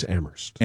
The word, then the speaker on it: Amherst: Don't pronounce the age, it's Amherst.